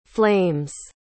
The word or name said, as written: flames